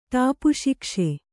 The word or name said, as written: ಟಾಪುಶಿಕ್ಷೆ